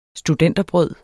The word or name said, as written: studenterbrød